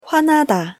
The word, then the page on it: 화나다: Korean